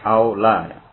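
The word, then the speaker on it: Aula (أولى): Al-man'utu imma ayakuna ma'luman biduni na'ti awalan biduni na'ti awla. Aula, bacanya bukan awalan, aula.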